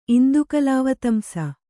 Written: ಇಂದುಕಲಾವತಂಸ

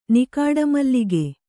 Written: ನಿಕಾಡಮಲ್ಲಿಗೆ

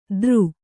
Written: ದರು

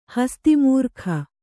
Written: ಹಸ್ತಿಮೂರ್ಖ

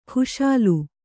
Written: ಕ್ಷಾಲ

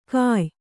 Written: ಘಾಯ

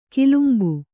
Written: ಕಲಂಬು

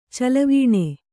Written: ಚೆಲುವಾನ್

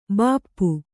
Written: ಭೂಪ

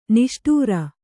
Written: ನೇಷ್ಟಾರ

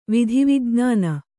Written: ವಿಧಿವಿಜ್ಞಾನ

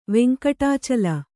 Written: ವೆಂಕಟಾಚಲ

